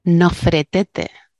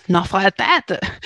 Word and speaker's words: Nofretete: Por ejemplo, a Nefertiti, los alemanes no la llaman Nefertiti. ¿Sabes cómo la llaman? – No. – Nofretete. ¿What? Nofretete. Pero porque en su día lo pronunciaron de otra manera.